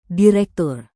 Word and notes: direktur: indonesian

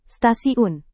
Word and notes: stasiun: Indonesian

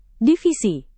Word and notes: divisi: Indonesian

